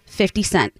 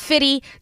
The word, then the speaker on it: 50 Cent: should always clearly pronounce 50 Cent. It is not Fitty. Don't say Fitty. Okay. It's never been Fitty. It's always been 50. It's 50 Cent.